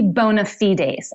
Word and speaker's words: bona fides: Latin bona fides. See, I'm smart. – Well, it would be bona fides, actually. – Ooh, ooh, ooh, ooh. You know, if you want the French pronunciation.